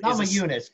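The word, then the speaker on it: Namajunas: hope I'm pronouncing that correctly, is Namahunas, Namahunas, Namahunas, Lithuanian, okay.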